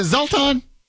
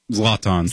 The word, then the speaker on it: Zlatan: Is it Zoltan? – Zlatan.